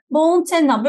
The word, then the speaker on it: Bountenna: Boğun Tenna böyle mi telaffuz ediyorsunuz hocam? Doğrudur evet hocam.